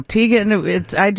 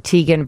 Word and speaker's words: Teigen: Oh, I saw her on the red carpet, and she said she pronounces it Teigen, but her father pronounces it Teigen. – Oh. – So it's really supposed to be Teigen, but she just says Teigen.